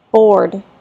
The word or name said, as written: board